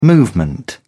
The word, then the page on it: movement: British English